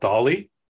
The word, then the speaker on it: DALL-E: It's called DALL-E. I assume it's pronounced like Salvador Dali, the Spanish artist.